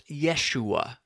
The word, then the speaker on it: Yeshua: And you might hear it pronounced Yeshua these days, as people realize that this is the proper Hebrew way of speaking the name of Jesus, Jesus in the Greek, Jesus in the English, and all that.